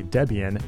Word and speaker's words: Debian: Debian is actually Debian, based on the names Deborah and Ian.